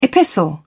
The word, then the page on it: epistle: British English